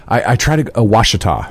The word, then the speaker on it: Ouachita: Ouachita. – Ouachita. – Yeah. – Ouachita. – It is a French spelling of an Indian name. When you have an O and a U, makes the W sound.